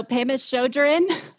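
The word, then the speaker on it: Pema Chödrön: One of my favorite books, and I always say her name wrong, Pema Chodron. Is that how you say it? – I believe so. Pema Chodron.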